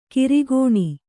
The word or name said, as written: ಕಿರುಕಣ್